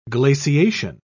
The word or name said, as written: glaciation